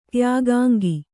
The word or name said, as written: ತ್ಯಾಗಾಂಗ